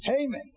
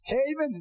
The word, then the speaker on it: Haman: King called in and had a great big banquet, and he said to Hanan, is that the way you pronounce his name? Y'all, Haman. Oh, you black folk, help me out. I'll say it like the black folks say it. Haman, is that it?